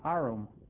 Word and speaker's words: arum: And Pat says, it's an arum. And Carol puts it in brackets. He pronounced it arum. Okay, A-R-R-U-M. That's the plant.